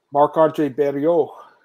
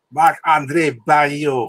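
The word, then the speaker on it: Marc-André Barriault: I want the perfect French pronunciation from you. – Marc-Andre Barrio. Is that good? – Marc-Andre Barrio or something. – I think that's pretty good. That's probably even better than mine.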